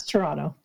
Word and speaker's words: Toronto: It's Toronto. – Toronto. Yeah. Silent T.